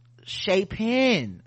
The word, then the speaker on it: Chapin: I and my family are from Chapin, South Carolina. That's how you pronounce it.